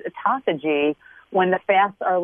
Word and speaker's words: autophagy: Autophagy. – You got to spell it. – I think it's A-T-O-P-H-O-G-Y. – I got to look it up. It sounds like esophagus.